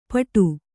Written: ಪುಟ್ಟಿ

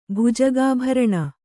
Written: ಭುಜಗಾಭರಣ

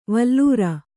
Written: ವಲ್ಲೂರ

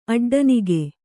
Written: ಅಡ್ಡನಿಗೆ

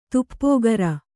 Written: ತಪಕೀರ

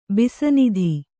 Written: ಬಿಸನಿಧಿ

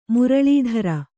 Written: ಮುರಲೀಧರ

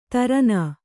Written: ತಾರೆಣ್ಣೆ

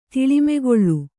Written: ಧೂಳಿಮಾಕಾಳ

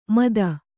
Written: ಮಥಿ

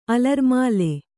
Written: ಅಲರ್ಮಾಲೆ

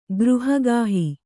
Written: ಗೃಹಗಾಹಿ

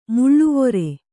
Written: ಮೇಲೆವರ್

